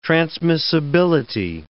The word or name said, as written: transmissibility